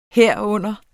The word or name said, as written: herunder